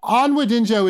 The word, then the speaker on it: Onwudinjo: And it's like, no, Onwudinjo is an unusual last name, but it's pronounced how it's spelled.